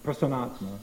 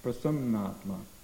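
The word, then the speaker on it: Prasannatma (प्रसन्नात्मा): So just like Dori Brahmabhuta Prasannatma, it's not Prasannatma, it's Prasannatma.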